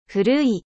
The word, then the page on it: vieux: French